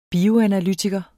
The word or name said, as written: bioanalytiker